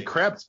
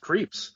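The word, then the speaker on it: Krieps: Yeah, I want to say Krebs, but it's pronounced Creeps.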